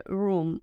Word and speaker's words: room: La palabra habitación se dice room, room. Lo más importante en la pronunciación de room es pronunciar la R suavecita, no ru, sino ru, ru, room, My room, mi habitación, my room.